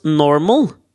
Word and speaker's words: NORML: Jeg tror Willy Nelson er med i den derre En organisasjon borte i USA som jeg syns har et fantastisk navn, som uttales Normal. – Men okay. det er et akronym, så det er NORML.